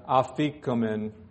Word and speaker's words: afikomen: This part is known as the afikomen.